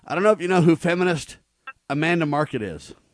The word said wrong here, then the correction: Marcotte: Marcotte, I think is how you say her name. – Lord. – M-A-R-C-O-T-T. I think it's Marcotte.